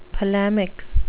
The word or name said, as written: polemic